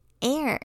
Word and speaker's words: heir: 这个头条的第一个单字是 Air。它是个名词，意思是继承人。要记住这个单字的 h 不发音。